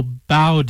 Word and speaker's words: bao: National Bow Day, I think that's how it's pronounced, B-A-O, like Bill Bow, honors the popular Chinese steamed bun filled with meats or vegetables.